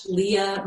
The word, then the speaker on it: Leah: It is just the uh, LinkedIn forward slash in forward slash Leah Marie Olszewski.